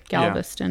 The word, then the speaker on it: Galveston: I live in Galveston, Texas." Did I say that right? Galveston, yeah.